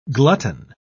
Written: glutton

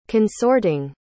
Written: consorting

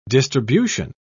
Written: distribution

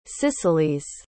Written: cicelies

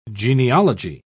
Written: genealogy

